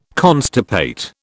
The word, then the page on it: constipate: British English